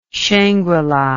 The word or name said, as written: shangrila